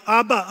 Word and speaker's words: Abba: It's not, they don't say it like Abba like we do, like the 70s band. They say it more like Abba, Abba, Abba.